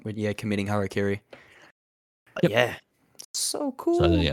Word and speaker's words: Harakiri: Yes, Harakiri.